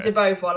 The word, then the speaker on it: De Beauvoir: This establishment has been closed, but it's been reopened under the name Des Beauvoirs Arms. – Right. – Did I even get close to pronouncing that right? – Beauvoir, I would say that. Beauvoir. – Okay. – De Beauvoir, like Simone de Beauvoir.